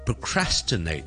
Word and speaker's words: procrastinate: 当我哋读 procrastinate 嘅时候，个 crass 系重音，咁我之前个 pro 咧就比较少咧，就会读成 pro 嘅，因为咁完整嘅音，我哋就将佢读成系好轻嘅，好 hea 嘅 pro 就变咗 procrastinate。